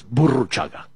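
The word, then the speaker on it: Burruchaga: First of all, it's Buruchaga. – Buruchaga. Yeah, I've never roll your R's.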